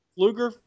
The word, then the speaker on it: Pfluger: Pfluger? – Yeah. – Okay. Well, why would they do that? There's a P in there. – The P. I don't know. The P is silent, brother, but...